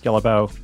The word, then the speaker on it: Guillebeau: I'm also sharing every day on social media under my name, Chris Guillebeau. That's G-U-I-L-L-E-B-E-A-U.